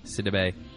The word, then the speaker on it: Sidibe: Sidibe, from Precious.